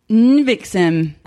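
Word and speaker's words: NXIVM: How do you pronounce it? – And then they're all, and then Miranda's like, Nvixim. She, like, knows the correct pronunciation.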